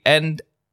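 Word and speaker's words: e:Ny1: Ik weet niet eens hoe je het moet uitspreken. E E-N-Y-1. Nou, dat doe je netjes.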